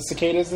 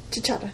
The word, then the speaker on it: cicada: They would say chichata, and I always would say call them chichatas because I didn't know it was cicada.